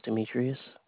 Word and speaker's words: Demetrius: Well, my name is Demetrius.